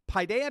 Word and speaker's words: paideia: It's spelled P-A-I-D-E-I-A, paideia.